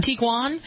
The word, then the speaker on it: Tiguan: Tiguan.